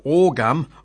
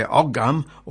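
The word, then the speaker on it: Ogham: The word today is, now you can pronounce it 2 ways, orgum or orgum. Now, should say ogum or orgum. It's O-G-H-A-M. Now, you can pronounce it O-G-U-H-A-M or A-W-G-U-H-M. So you can pronounce it either way.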